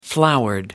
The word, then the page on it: flowered: British English